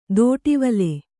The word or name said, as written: ದೋಟಿವಲೆ